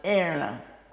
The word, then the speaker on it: Erna: And my first name is Erna, and in German it's pronounced Erna.